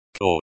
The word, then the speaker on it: Кот: Кот. Cot, the O, sounds like the O, in, cot.